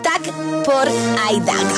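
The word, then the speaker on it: tak for i dag: Y la frase es tak por aidag. – Tak por aidag.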